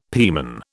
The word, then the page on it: pieman: British English